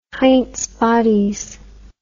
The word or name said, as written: heinz bodies